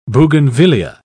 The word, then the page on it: bougainvillaea: British English